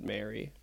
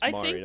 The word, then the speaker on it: Mari: I thought it was said Mary. Who's... How the fuck would you ever know that's pronounced Mari that way? M-A-R-I. Because she said it on the show.